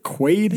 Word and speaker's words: Quaid: Am I saying it right? Quaid? – Yeah, Randy Quaid.